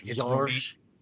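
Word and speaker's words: Gheorghe: It's Georges. – Georges, maybe it's G-H-E-O-R-G-H-E. But I saw it like the pronunciation guide laid it out as like two syllables. So it was like almost like Georges.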